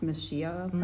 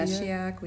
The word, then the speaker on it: Mashiach: the Hebrew word for anoint would have been pronounced Mashiach, probably, mm-hmm. – Mashiach. Or Mashiach.